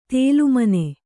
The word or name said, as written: ತಾಲಮಾನ